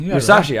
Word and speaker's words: Musashi: And it was finally, what happens is, I said it, Musashi. Like sushi? Musaki? How do you say Musashi. – It? Musashi. Right. I did say it right.